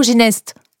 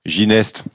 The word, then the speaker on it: Gineste: C'est Castelginet ou Ginest ? – Gineste, vous savez, Gineste. Ici on prononce toutes les lettres.